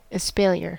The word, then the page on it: espalier: American English